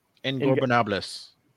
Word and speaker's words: Ingobernables: We move on to the Blackpool Combat Club versus Ingobernables. I say that wrong. How do you say their name? – Ingobernables.